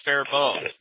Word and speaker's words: Faribault: And this town is, it's called Faribault, Minnesota, and it's spelled Faribault, but it's pronounced Faribault.